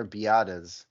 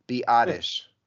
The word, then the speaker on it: Biadasz: And it's the first hit that came up, which is Wikipedia, which is always right, is Biadasz. – Biadasz. Tyler Biadasz. – According to Wikipedia, it's Biadasz. – The classic silent Z on the end of it.